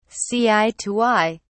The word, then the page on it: see eye to eye: British English